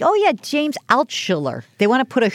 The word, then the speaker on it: Altucher: We're talking to James Altucher. It is the Jill on Money Show. Oh, I said it wrong. Altucher. – Yeah, Altucher.